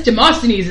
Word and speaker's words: Demosthenes: Demosthenes.